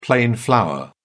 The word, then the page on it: plain flour: British English